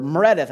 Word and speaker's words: Meredith: My name is Meredith, or Meredith, as I like to pronounce it.